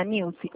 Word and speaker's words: Nilce: Por gentileza, nesse telefone eu consigo falar com a senhora Nilze? – Sou eu. Quem é? – É, desculpa, é assim que se pronuncia? É Nilze ou é Nilce? – Nilce. – Ah, tá. Dona Nilce, o meu nome é Graziele Santos, eu falo da Atencrédito, eu sou representante autorizada do Banco do Brasil.